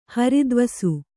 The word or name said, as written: ಹರಿದ್ವಸು